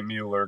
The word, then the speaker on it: Mueller: it's easier to for spelling purposes for people if I just say Mueller because then they they they don't think they don't think it's spelled that way when I say Miller.